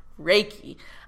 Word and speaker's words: Reiki: Ray, like Ray. Reiki. So if you've been saying it wrong, guys, it's not Reiki.